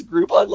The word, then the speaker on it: Groupon: Jan pronounces Groupon like it's Grey Poupon. – What? – She really does. – She puts the accent on the P. – Groupon. – I've tried to explain it so many times that it rhymes with coupon.